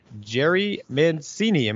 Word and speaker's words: Jerry Mancini: Today, Martin is not on the show, but today we have a special guest, Jerry Mancini.